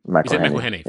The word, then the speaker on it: McElhenney: I don't know how to say his surname, but that's, yeah, is it McElhenney? Yeah. Fine.